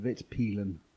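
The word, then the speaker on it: Vitpilen: Svartpilen and Witpilen. – Thank you for the correction.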